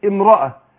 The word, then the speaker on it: امرأة: يبقى اقول او ام يبقى بنطق بعد الواو الميم لان الهمزة بتسقط همزة الوصف تسقط في وسط الكلام وتثبت في اول الكلام. يعني لو بدأت بكلمة امرأة هثبت الهمزة اقول امرأة. لكن لو في وسط الكلام الهمزة اقول اوم اوم يبقى سقطت ايه في الدرج في وسط الكلام.